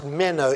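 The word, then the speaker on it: meno (μένω): Well, it's a Greek word which is pronounced meno, M-E-N-O,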